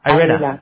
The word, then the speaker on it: Irena: It's with an R. I-R-E-N-A. Most people just call me Irene, honestly, because they can't say my name. I get called Irene, I get called Reina, or, whatever. – Irena.